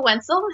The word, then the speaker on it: Wensel: So my name is April Wensel. It happens to rhyme with pencil.